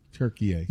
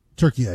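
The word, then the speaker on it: Türkiye: Turkey A just decided to change the pronunciation of their country about 2 years ago. That threw me for a loop. I had to cover this more aggressively for the Euros. And so I made that mistake a couple times of pronouncing it incorrectly.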